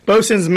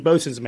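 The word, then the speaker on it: boatswain's: Bosun's mate. – What's this word? Boat? That's spelled boatswain, yeah. – Right? – Yeah, boatswain. Boatswain and then mate, M-A-T. Bosun's mate.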